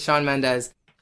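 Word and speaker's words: Shawn Mendes: Hey, guys, it's Sean Mendes. – Well, I'm not hearing it. – Mendes. – Hey, guys, it's Sean Mendes. – Say it. Try it. – Sean Mendes.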